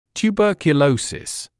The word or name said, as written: tuberculosis